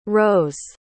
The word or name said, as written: roes